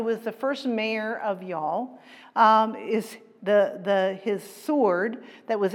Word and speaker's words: Youghal: It's Youghal. I'm not being Texan here. It's pronounced Youghal, and it's in the southeastern coastline of Ireland.